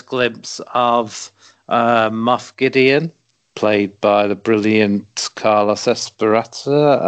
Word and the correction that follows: Esposito: Yeah, Giancarlo Esposito.